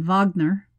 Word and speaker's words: Wagner: First of all, the Wagner Group, or you would pronounce it in Russian, Wagner, could really be straight out of fiction.